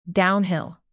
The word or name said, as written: downhill